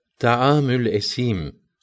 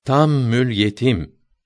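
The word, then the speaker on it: taamül esim (طعام الأثيم): Mesela Abdullah İbni Mesud radıyallahu teala an taamül esim kelimesini mütemadiyen tammül yetim diye okuyan bir köylüye sen bu kelimeyi telaffuz edemiyorsan, bunun yerine aynı manada olan taamül facir kelimesini kullan demişti. Fakat Kur'an-ı Kerim'in böyle muhtelif lehçelerle okunması,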